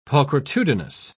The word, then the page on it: pulchritudinous: British English